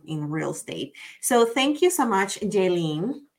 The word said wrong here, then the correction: JaLynn: And my name is pronounced Jalen. It's for all you that are listening. I always tell everybody, it's just think of Jay Leno. Just take off the O. Jalen.